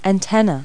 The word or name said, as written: antenna